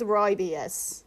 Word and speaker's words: rooibos: Twinings, rooibos. – I think it's rooibos. – I think it's rooibos or rooibos. – No, it's rooibos, and everyone who thinks otherwise can fight me.